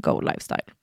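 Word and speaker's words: Goe Lifestyle: Men stavas G-O-E. Mm. Men vi uttalar den Go Lifestyle.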